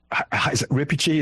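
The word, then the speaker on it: Reepicheep: Lewis, who said, you know, Rippichi, is that how you say his name? – Yeah.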